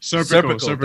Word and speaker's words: Serpico: Serpico.